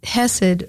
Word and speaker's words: chesed: It's hesed. In English, you would spell it C-H-E-S-E-D. In Hebrew, it's chet, samek, dalet.